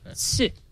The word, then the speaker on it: Tsu: S-U-E? Or? – T-S-U. Oh, okay. – The T is silent. – I thought it was like the legal action. – I believe it's because it's Asian made. And in Japanese, if you pronounce something that's T-S, it would be Sue with the S. – Sue. – Yeah, Sue. Like tsunami.